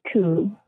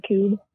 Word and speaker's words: Kubb: Speaking of that, okay, we played Cube. Have you guys ever played Cube? – No. Like how do you spell it? – K-U-B-B.